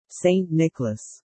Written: Saint Nicholas

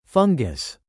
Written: fungus

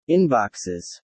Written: inboxes

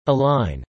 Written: align

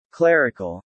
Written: clerical

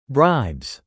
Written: bribes

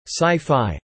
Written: Sci-Fi

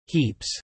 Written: heaps

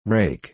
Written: rake

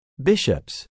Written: bishops

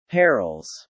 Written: perils

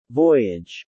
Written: voyage

